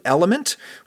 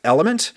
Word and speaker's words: LMNT: Element, which is spelled L-M-N-T. But it is pronounced Element. – Element.